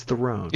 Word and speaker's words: Theron: Am I pronouncing that right? It's Theron.